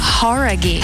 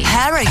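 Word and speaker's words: Jauregui: Horaghi. Who é? – Horaghi. – Horaghi. – Horaghi. Ah, então a gente tava falando errado, cara.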